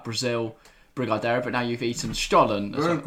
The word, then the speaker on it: Brigadeiro: the mince pie is better than the Brigadeiro. – Brigadeiro.